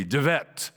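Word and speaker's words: De Wette: a German scholar, a skeptic named De Wet, spelled with a W but pronounced with a V, De Wet, came along and said, I have a theory.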